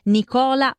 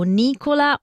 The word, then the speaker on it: Nicola: Nicola o Nicola, un accento che cambia dall'italiano all'inglese e con esso cambia anche l'attribuzione del genere di chi porta questo nome, ma Nico è orgogliosamente a suo agio indossando entrambi, nome e abiti, sul palco e nella vita.